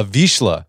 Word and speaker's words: Vizsla: And in this book, you take readers through a midlife transformation that was kickstarted after your family got a Vizsla. Is that how you pronounce it? – Vizsla that you named Duder.